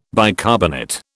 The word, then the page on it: bicarbonate: British English